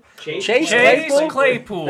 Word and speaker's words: Claypool: Oh yeah, Clay's Chapel Playchul. How do you say Chase Claypool? – That guy.